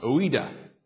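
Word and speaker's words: oida (οἶδα): So remember gnosko, and remember the word that John uses here has this root, a root that's pronounced oida.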